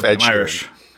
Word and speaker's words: Inisherin: But I saw The Banshees of Inisherin last week. – Is that how you pronounce it? – Yes. There's a little I in there. – Banshees of Inisherin.